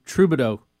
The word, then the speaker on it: Trudeau: Yeah, well, let's look at Justin Trudeau.